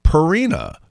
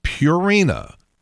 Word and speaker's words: Purina: They're all saying Purina. And it's not Purina, it's Purina, like pure.